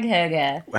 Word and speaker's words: hygge: H-Y-G-G-E. So you want to pronounce it hygge. – Which I would have spelled H-U-G-U. – No. – With maybe an umlaut in there. – It's hygge.